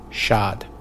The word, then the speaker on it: Chad: The French don't pronounce the T in that, so in English we just heard the French pronunciation Chad.